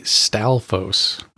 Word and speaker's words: Stalphos: What I have been calling Staphlos this entire time is actually Stalphos. I had the L and the F mixed up the entire time like a fucking idiot.